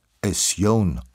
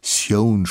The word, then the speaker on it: Sjón: Sjón spricht man das aus, so wie in Vision.